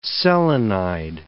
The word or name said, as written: selenide